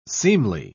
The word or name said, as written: seemly